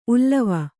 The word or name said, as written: ಉಲಿವು